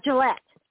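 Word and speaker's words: Gullette: Sean Gillette, I think that's how you say his name? – Gillette. – Okay, I never know French, pronounce that E at the end or not, you know.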